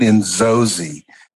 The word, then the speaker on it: Inzozi: How do you say that? – You say it Inzozi.